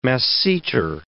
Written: masseter

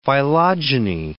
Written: phylogeny

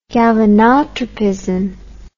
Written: galvanotropic

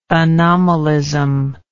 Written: anomalism

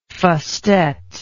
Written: fustet

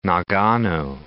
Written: Nagano

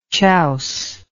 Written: chouser

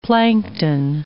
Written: plankton

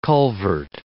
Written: culvert

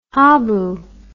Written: habu

